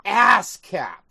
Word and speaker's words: ASCAP: Say it right, Judy. It's ASCAP. ASCAP. It is. Like an ass for your head.